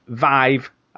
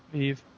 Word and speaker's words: Vive: PlayStation VR will work with PlayStation Vive, as we now know it's pronounced, and Oculus will work with PC. – Vive.